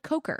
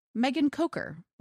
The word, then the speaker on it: Kocher: But Megan sent us an email that said it's pronounced with a long O and a hard CH, like Coker. – Oh. – Megan Coker.